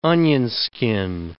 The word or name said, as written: onionskin